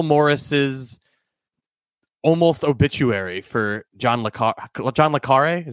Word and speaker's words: le Carré: Is that how you pronounce it? I think that's the movie said accent, it. The movie said it, and I was like, oh, I thought it was John le Carré. I was wrong.